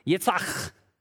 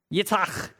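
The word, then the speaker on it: Yitzhak (יצחק): In Hebrew, Yitzhak. Yitzhak. You say it right when something lands on the back of the person sitting in front of you.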